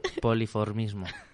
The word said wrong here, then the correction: polimorfismo: No, es, es polimorfismo.